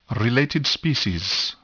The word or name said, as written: related species